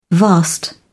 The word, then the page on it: vast: British English